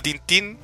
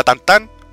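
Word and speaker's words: Tintin: ¿Tú sabes que no se dice Tintín? Se llama Tantán. – ¿Qué? – ¡Jaleo! – ¿Qué? – ¿Sí? – ¿Qué? – Tintín se llama Tantán. Se pronuncia Tantán. – En francés.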